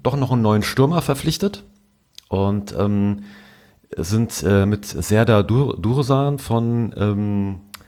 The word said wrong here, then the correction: Dursun: Ich muss mal aufpassen, dass ich das richtig ausspreche, ja, Dursun. – von Dursun, genau, vom Greuther Fürth zu uns gekommen.